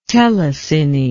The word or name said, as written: telecine